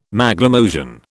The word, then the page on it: Maglemosian: British English